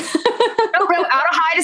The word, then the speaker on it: Adelheid: Adelheid. Is that pronounced wrong? – No, Adelheid is correct.